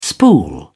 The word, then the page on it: spool: British English